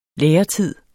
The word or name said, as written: læretid